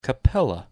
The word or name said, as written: Capella